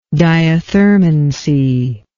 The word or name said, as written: diathermanous